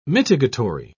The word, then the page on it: mitigatory: British English